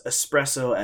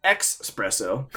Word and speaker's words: espresso: when people pronounce espresso as expresso, or when people say frustrated instead of frustrated.